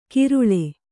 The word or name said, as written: ಕಿರುಳೆ